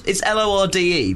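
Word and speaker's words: Lorde: Is it pronounced? It's L O R D E.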